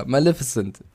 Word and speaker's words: Maleficent: Also ja, Maleficent, so spricht man es aus, bitteschön.